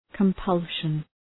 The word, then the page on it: compulsion: {kəm’pʌlʃən}